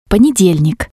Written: ponedelnik

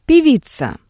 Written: peviza

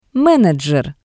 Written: menejer

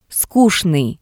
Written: Skutsnii

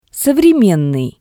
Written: sovremennii

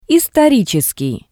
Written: istoritseskii

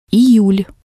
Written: iyul

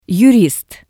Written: yurist

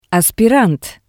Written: aspirant

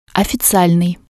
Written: ofizialnii

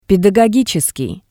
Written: pedagogitseskii

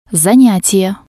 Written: zanyatie

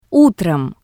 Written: Utrom

